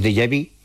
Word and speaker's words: déjà vu: lo que llamamos déjà vu, que eso, mira que bien lo pronunciamos. – Sí, muy bien, muy bien. Déjà vu, sí.